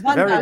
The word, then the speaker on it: Vandaag: ESPN Vandaag, is that right? – ESPN Vandaag, very well pronounced. Vandaag.